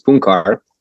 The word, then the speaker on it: Puncar: It was actually originally Puncar. It's like the soft C there, but it would be too hard probably to pronounce for people. So I just stick with a regular C.